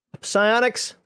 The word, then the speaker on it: psionics: You have a psychionics here, psionics. That's right. I pronounce the P.